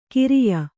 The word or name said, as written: ಖರೆಯ